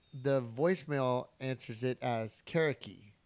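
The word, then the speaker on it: Carriqui: Kariki.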